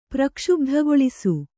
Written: ಪ್ರಕ್ಷುಬ್ಧಗೊಳಿಸು

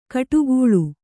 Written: ಕೋಡ್ಗಲ್ಲು

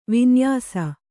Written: ವಿನ್ಯಾಸ

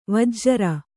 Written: ವಜ್ರಿ

